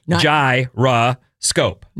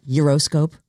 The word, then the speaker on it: gyroscope: It's pronounced with three syllables, gyroscope. – Not euroscope? – No.